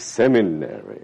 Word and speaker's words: seminary: I always mispronounce that word. Not cemetery, seminary. You better learn it correctly. Seminary.